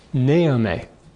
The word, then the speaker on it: Naomi: They called her Naomi. The emphasis on the first syllable, rather than on the second syllable, Naomi.